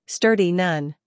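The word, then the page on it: sturdy: uk /ˈstɜːdi/; en-us /ˈstɜːrdi/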